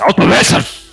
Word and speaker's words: Altomünster: Altomünster. Eu tô pronunciando errado, certamente. – Um alemão, tem que falar xingando, cara. Altomünster!